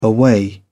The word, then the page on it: away: British English